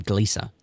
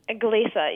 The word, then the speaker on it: Gliese: So that's how you say it, Gliese. – Gliese, yes.